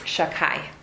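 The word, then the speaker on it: Shakai (שדי): Let's look at the name Shaddai, which we pronounce Shakai.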